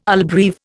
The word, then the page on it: alla breve: Indian